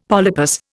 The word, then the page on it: polypus: Indian